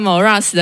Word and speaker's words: Xmoraz: 你可以跟大家说一下 Morass 是什么样的乐团吗？比如说曲风啦等等之类。 – Morass 是金属乐团，然后我们就是主要玩 Metalcore、 Post-Hardcore 这样。对，然后我们是一个温暖的金属乐团。 – 怎么样？怎么样叫温暖？ – 温暖。 – 就哦，好，那那个 Morass 的 X 不发音，然后 X 前面它是 cross 的意思，嗯嗯，然后 Morass 是沼泽的意思，嗯，所以我们团名叫做跨越沼泽，嗯，就是希望我们可以帮助大家跨越人生中的困境，这样。